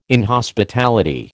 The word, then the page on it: inhospitality: American English